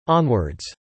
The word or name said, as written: onwards